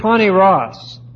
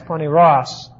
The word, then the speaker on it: poneros: Now, an evil conscience, this is the word poneros, which is the stronger of the terms for evil in the New Testament. P-O-N, long E-R-O-S, poneros. Poneros